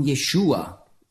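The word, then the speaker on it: Jeschua: Orthodoxe Juden sprechen nicht von Jeshua. Das ist der Name Jesus auf Hebräisch ausgesprochen.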